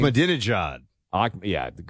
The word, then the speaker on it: Ahmadinejad: Mahmoud Ahmadinejad or whatever he pronounces his name. – Ahmadinejad.